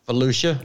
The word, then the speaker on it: Volusia: Yeah, in, I don't know how you say it, V-O-L-U-S-I-A County. – Volusia? – Volusia County.